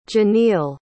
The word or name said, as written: Jeneil